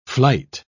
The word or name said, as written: flight